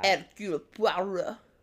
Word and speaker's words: Hercule Poirot: Hercule Poirot, which I just, like, really butchered that name, but that's Agatha Christie's guy. – Hercule Poirot. – That was really good.